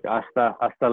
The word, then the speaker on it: hasta: Like hasta luego. That's, you know, like hasta is a yeah, H but you word. Don't. Oh yeah, because you don't pronounce the H very often, and that's an easy way to say if you're a gringo.